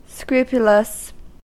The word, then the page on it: scrupulous: American English